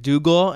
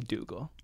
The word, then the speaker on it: Dougall: Well, you can follow me on YouTube just at my name, Patrick Dougal, and then on social, Twitter, and Instagram at Patrick A. Dougal. – And Dougal is spelled like Doug. – Doug All.